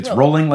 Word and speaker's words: Rowling: I think how Rowling, rolling like bowling. Yes. pronunciation dance. Did it right.